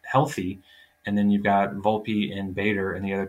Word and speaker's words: Volpe: Volpe.